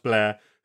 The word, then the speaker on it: Bler: And there have been one or two people, in fact, one, I think he's just called Blair, B-L-E-R, but pronounced Blair, who became a footballer, you know, fairly nationally recognised guy in Kosovo.